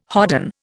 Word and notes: hodden: Indian

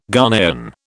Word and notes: Ghanaian: British English

